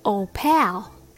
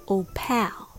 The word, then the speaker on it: opal: I said, Old pal. You know, it's a beautiful stone. But I pronounced it wrong. It was opal, not opal. So I learned the lesson and I learned how to say it right. The word Opal has the stress on the first syllable, O.